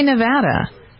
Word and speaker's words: Nevada: N-E-V-A-D-A. – I say Nevada, but a lot of people say Nevada.